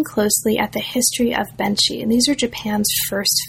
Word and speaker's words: Benshi: So the first part of the book looks at early Japanese film stars, and it focuses mainly on Benshi. Am I pronouncing that correctly? – Yes. – Benshi, great. Benshi, and on a particular figure, a particular Japanese film actor as well.